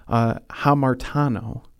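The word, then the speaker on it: hamartano (ἁμαρτάνω): The word there that Paul uses that is translated as sinned in the Greek, which I know you don't care what the word is pronounced as, but it's hamartano.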